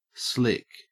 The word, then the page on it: slick: Australian English